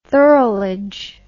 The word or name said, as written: thirlage